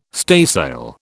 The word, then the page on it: staysail: British English